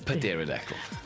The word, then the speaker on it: pterodactyl: Pterodactyl. – Nou wil ek my grap weer vertel. Hoekom kan jy nie 'n pterodactyl oor piepie in die woud nie? – Hoekom nie? – Because the P is silent.